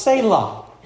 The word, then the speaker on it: Selah: this article written, uh, pronounced Selah.